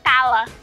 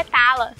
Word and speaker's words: Tyler: Eu sou o Husky Tyler Slade, leia Tala. Ah, então é Tala, como a sílaba tônica no A.